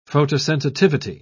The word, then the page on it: photosensitivity: British English